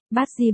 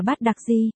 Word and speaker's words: bát đặc di: Con người, bát di vốn nên đọc là bát đặc di,